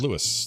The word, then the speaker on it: Luis: I think it's pronounced Luis. L-U-I-S. Louis? Louis? – Louis. – Louis, right? Louis. – Yeah, like from Sesame Street, up the street.